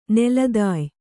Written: ನೆಲತಾಯಿ

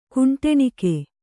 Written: ಕುಂಟಣಿಗ

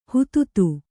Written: ಹೂತೂತು

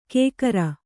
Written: ಕೇಕರ

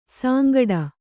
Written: ಸಾಂಗಡ

